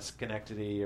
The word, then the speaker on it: Schenectady: And that's not, I actually really like Schenectady or New York. I can never say it correctly. – You said it right.